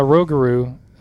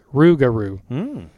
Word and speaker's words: rougarou: She said, on the Rogaroo. I've always heard that it's pronounced Rougarou, like the last syllable in kangaroo.